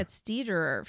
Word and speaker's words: Dederer: Yeah, it's Dederer. Doesn't rhyme with Federer, yeah.